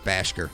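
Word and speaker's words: Bhasker: That's just Jeff Bashker. am I not saying that right? – No, you're saying it right.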